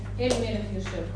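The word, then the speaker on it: Эль Мелех Юшев (אל מלך יושב): Вот это вот Кель Мелех Юшев. Кен? Кель Мелех? – Нет, Эль Мелех Юшев.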